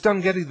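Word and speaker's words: Dunghutti: Is Dangati the right way to say it, by the way? – Yeah, that's not bad at all.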